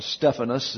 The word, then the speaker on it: Stephanos: the Stephanos, or Stephanos is the way it's pronounced in the Greek.